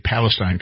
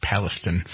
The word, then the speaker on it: Palestine: I keep wanting to say Palestine because that's the way, but they pronounce it East Palestine.